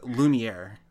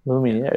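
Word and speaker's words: Lumière: The name is Lumiere. Lumiere. – Are you kidding me? – Yeah. – Lumiere.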